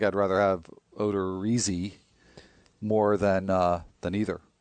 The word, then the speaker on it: Odorizzi: Odorizzi. – Whose name I just pronounced differently the second time because that's the correct way. Baseball Reference has informed me.